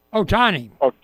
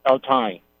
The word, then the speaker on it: Ohtani: Ohtani. – Ohtani.